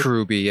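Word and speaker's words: Kuroobi: Is it Kurobi? Is that how you pronounce it? – Kurobi, yeah.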